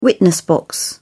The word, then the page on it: witness box: British English